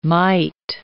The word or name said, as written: mite